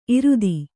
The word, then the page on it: ಈರಿತ: Kannada